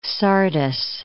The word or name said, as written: Sardis